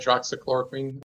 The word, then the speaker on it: Hydroxychloroquine: Hydrochloroquine, I think, is that how you pronounce it? Hydroxychloroquine.